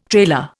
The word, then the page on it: trailer: Indian